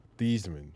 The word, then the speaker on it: Theismann: Theismann. – He changed his name to rhyme with the Heisman.